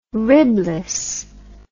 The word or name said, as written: ribless